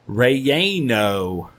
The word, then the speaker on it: relleno: Relleno. I'll have a chili relleno. – That's how you say it.